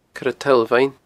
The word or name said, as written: Coire Toll Bheinn